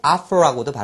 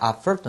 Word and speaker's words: offer: 옷 for 옷을 막 퍼준다 offer 또는 offer라고도 발음합니다 offer 또는 offer 자 offer 옷을 막 퍼준다 알겠죠?